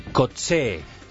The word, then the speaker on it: Coetzee: Coetzee, el sudafricano, Coetzee,